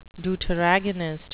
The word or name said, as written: deuteragonist